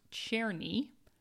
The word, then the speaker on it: Czerny: She also said that the composer we could not quite pronounce the name of correctly is pronounced Czerny. – Hmm. – That's C-Z-E-R-N-Y. Czerny.